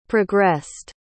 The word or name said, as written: progressed